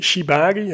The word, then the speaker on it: shibari: Oké, het woord is, ik zal het nog een keer duidelijk uitspreken, shibari.